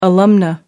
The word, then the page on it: alumna: American English